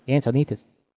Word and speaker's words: Encinitas: I think in Spanish, Tom, you pronounce that Encinitas.